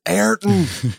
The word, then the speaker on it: Ayrton: How many times can I say Art in the wrong way? Ayrton. – It's Ayrton. Say his name right, you damn yank.